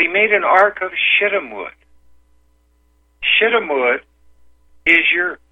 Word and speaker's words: Shittim: S-H-I-T-T-U-M. Pronounced shittim. So I did not say S-H-I-T. I said a word that is in the Bible.